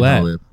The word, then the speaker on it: Nolet: Nolet is actually Nolet.